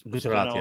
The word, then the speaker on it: Gujarat: subtitle says the documentary critical of the of PM Modi's role in the deadly 2002 uh, Gujarat riots. Did I say it right? – Gujarat, yeah, said, yeah, Gujarat.